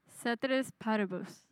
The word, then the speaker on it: ceteris paribus: Ceteris paribus.